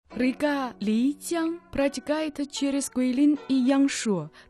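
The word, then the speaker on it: Лицзян (丽江): Лицзян. Там Ли произносится в четвертом тоне.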